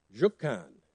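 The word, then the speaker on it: Rjukan: But in Norway, there's a town called Jokkan. Now, you know how I knew how to say that? You Google it and it says pronounce. And so it's R-J-U-K-A-N. They must not pronounce the R.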